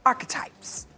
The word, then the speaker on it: Archetypes: Yes, Miss Meghan Markle invited her to appear on her now canceled podcast, Archetypes.